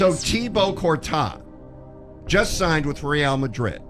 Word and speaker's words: Thibaut Courtois: All right, Real Madrid goalkeeper Thibaut Courtois, pronounced Thibaut Courtois.